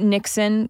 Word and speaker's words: Niksen: Now, this is a woman who wrote an article for the New York Times in 2019 about Niksen, which isn't even really... – It's tough to say. I wish it wasn't pronounced Niksen.